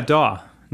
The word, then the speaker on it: DAW: Did I say it right? DAW? – Yeah, DAW. Not to be confused with dog, which we have two in the room.